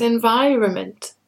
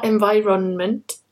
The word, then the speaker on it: environment: Normally in English when we have the suffix ment, there's an N before it. And that N is silent. So it's environment, government, not environment and not government.